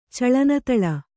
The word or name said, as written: ಚಳನತಳ